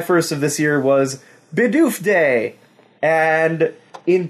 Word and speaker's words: Bidoof: Oh, we got Bidoof Day! July 1st was Bidoof Day. Or Bidoof, depends on your pronunciation. – What the frack is that? – It's a Bidoof!